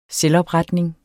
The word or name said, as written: selvopretning